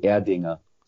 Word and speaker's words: Erdinger: It's in Germany, I think they pronounce it Erdinger.